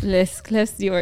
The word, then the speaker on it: Les Clefs d'Or: It's called Les Clefs d'Or. – Yeah. – Which is probably not how you say it. – Les Clefs d'Or, it sounds right.